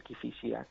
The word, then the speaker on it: Kifisia: Exactamente, exactamente, el Kifisia, claro, Kifisia, bien, bien pronunciado, sí señor.